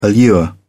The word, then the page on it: allure: British English